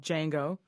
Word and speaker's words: Django: Jamie Foxx plays a former enslaved American called Django. The D is silent.